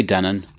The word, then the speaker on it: Denon: Is that how you pronounce the company, Denon? I thought it might be like Denon. – Some people say Denon. I say Denon.